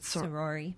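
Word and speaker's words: Saori: In just right around the corner is a sayori. I think that's how you say it. – Sorori. – Sorori. – Mm-hmm. Weaving. – Oh, that's a word that does not pronounce like it's written.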